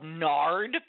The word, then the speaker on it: nard: I'd like a bottle of nard, please. Rhymes with lard.